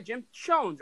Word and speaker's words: Chones: with Jim Chones. Uh, mm-hmm. my, that's pronounced right, Jim Chones, right? Jonas. Conan? – It is. It is Jim Chones and uh, Tim Alcorn does play by play now.